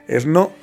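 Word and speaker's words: Ernő: En 1974, un escultor, que además era profesor de arquitectura, de nombre Ernaud, y me confundí para pronunciarlo porque es un acento en la O, pero además es una palabra rave. Bueno, más bien sería... No, sí es rave porque está en la segunda sílaba.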